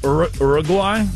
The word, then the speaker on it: Uruguay: Can you say it as a non-American? – Well, it doesn't start with a your sound. – Oh. – Uruguay? – Uruguay.